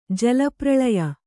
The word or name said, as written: ಜಲಪ್ರಲಯ